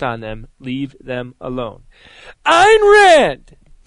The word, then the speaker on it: Ayn Rand: Because she's going to be incredibly impressed with how I pronounce this next person's name. This is a quote from Ayn Rand. – Good. Very good.